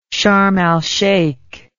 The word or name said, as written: sharm al sheikh